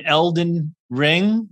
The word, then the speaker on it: Elden Ring: And you know, of course, the legendary DJ, Daniel, who is uh, often twitching the new show, uh, new game Elden Ring.